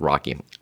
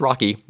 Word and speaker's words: Roky: I always call him Roky because it's R-O-K-Y, but it's pronounced Rocky. So I, in my head, I always thought it was Roky, and then someone told me, no, that's Rocky.